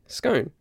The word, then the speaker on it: scone: So I say scone, and it rhymes with bone.